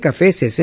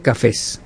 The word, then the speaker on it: cafés: Eso equivale a muchos cafés No se dice cafeses, ¿eh? Se dice cafés. Porque el otro día había uno, ¡ay, qué bonita muchacha de ojos cafeses! No, es cafés.